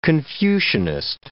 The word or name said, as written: Confucianist